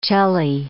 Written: celli

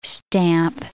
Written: stamp

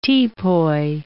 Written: teapoy

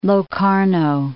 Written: Locarno

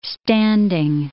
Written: standing

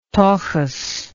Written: tokus

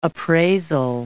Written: appraisal

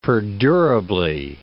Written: perdurably

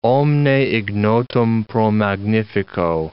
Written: omne ignotum pro magnifico

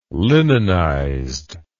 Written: linenized

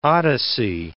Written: odyssey

